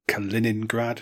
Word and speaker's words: Kaliningrad: And it was a series of checkpoints that you had to capture before the armoured column rolled through to Kaliningrad.